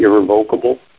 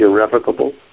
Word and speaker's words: irrevocable: Just to be pedantic, that's the correct pronunciation of this word. It's not really irrevocable. It's irrevocable. Anyway.